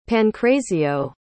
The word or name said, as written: pancrazio